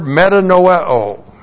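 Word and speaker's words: Metanoeo (μετανοέω): First of all, there is a Greek transitive verb, metanoeo. Looks like that in the Greek, M-E-T-A-N-O-E, long O. Metanoeo.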